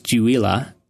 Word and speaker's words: Uila: So UILA is a Hawaiian word. It's actually pronounced Juila, and it is in reference to kind of the Hawaiian concept of electricity or lightning.